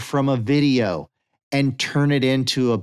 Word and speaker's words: Vidyo: The next one is called Video, but Video is spelled V-I-D-Y-O, Video.